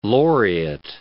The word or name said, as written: laureate